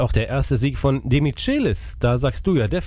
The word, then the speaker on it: Demichelis: Ist mir egal, wie es richtig ausgesprochen wird übrigens, Demichelis. Man spricht den Demichelis aus, Punkt aus.